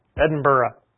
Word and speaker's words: Edinburgh: I mispronounced it. Edinburgh. It was originally erected to commemorate the fallen soldiers in the Napoleonic Wars.